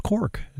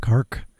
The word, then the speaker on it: Cork: It's not pronounced Cork. It's pronounced Cork.